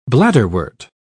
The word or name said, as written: bladderwort